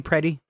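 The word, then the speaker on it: Pretti: Is that how they say his name, Preddy? – Yes. – Preddy.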